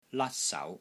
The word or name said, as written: LAT1 SAU2